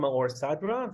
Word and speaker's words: Maor Sadra: So I'm Maor Sadra. That's how you pronounce my name.